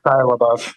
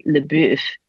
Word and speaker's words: LaBeouf: Shia LaBeouf. – Yeah, Shia LaBeouf. – Yes, the name that I can't pronounce because it looks like it should be LeBeouf. – LeBeouf. – Or just the beef, right?